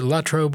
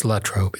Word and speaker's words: Latrobe: So is it Latrobe or is it Latrobe? It's Latrobe. It's always been Latrobe. Pronounced it La-trobe. So I and many others have been flat wrong all these years.